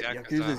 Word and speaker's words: Yakuza: I'm streaming Yakuza, Yakuza, I don't know how to pronounce it properly. I can barely speak English. – I think it's Yakuza.